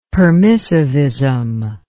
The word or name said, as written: permissivism